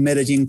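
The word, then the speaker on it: Medellín: So it's 7 in the morning over here in Medellin, Colombia.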